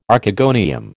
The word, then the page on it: Archegonium: American English